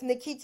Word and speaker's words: Nkechi: So that's Nkechi.